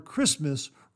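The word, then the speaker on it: Kiritimati: The name is derived from the English word Christmas, written in Gilbertese according to its phonology, in which the combination TI is pronounced S, giving Christmas.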